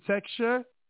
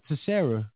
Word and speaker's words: Teixeira: So is it Texture? Texture? – Bro, what? Teixeira.